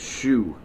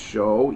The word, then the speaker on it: shew: Shoe. You know, it's actually that S-H-E-W is actually pronounced show, even though it's written S-H-E-W.